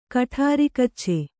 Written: ಕಠಾರಿಕಚ್ಚೆ

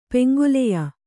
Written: ಪೆಂಗೊಲೆಯ

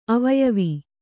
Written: ಅವಯವಿ

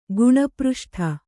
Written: ಗುಣಪೃಷ್ಠ

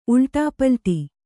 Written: ಉಲ್ಟಾಪಲ್ಟಿ

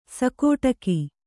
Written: ಸಿಕ್ಕಟ್ಟಿಗೆ

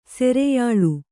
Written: ಸೆರೆಯಾಳ್

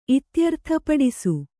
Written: ಇತ್ಯರ್ಥಪಡಿಸು

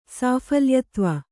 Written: ಸಾಫಲ್ಯತ್ವ

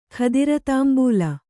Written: ಖದಿರತಾಂಬೂಲ

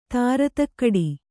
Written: ಧೀರತೆಗಿಡು

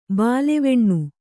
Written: ಬಾಲೆವೆಣ್ಣು

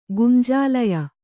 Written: ಗುಂಜಾಲಯ

